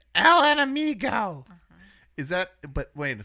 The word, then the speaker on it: Enemigo: El Enemigo. – You guys both talked down to me about it. You did. – Whatever. – Rarely there's a hard G.